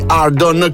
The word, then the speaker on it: Ardn: Ardon, lui si scrive A-R-D-N, dovrebbe pronunciarsi Ardon con Retail.